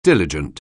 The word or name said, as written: diligent